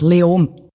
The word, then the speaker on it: Leoben: Ich spreche sie wahrscheinlich falsch aus. Spricht man es Leoben aus? Wie spricht man das überhaupt aus? – Fast kein Konsonant. Leom.